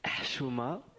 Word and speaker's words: Chouma: Alors, je prononce bien Chouma ? – Non, vous ne prononcez pas très bien. – Je m'en doutais. – Ça se dit Chouma. Donc avec un H comme quand on a bu du coca.